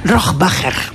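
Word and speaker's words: Rohrwacher: Bueno, ¿cómo se pronuncia Alba Rohrbacher Cochet? – Rohrbacher. – Rohrbacher.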